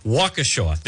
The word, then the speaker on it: Waukesha: They talk about Winika. I already forget how to pronounce that. Where the Christmas parade was. Waukesha. There we go.